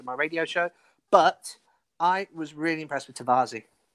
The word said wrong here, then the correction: Tavares: Tavares. – I can't pronounce it. Tavares.